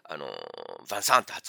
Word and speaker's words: ヴァンサン: あのヴィンセントって書くんですけども、まあフランス語、フランス映画なんで、ヴァンサンって発音してるんですけど、これドイツ語バージョンだとあの違う発音になってるんですね。フィンセントになるのかな。